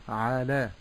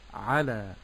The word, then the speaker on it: على: قوله تعالى على، لا أقول على بمد العين، ولكن أقول على، استمع جيدا، لا أقول على ولكن أقول على.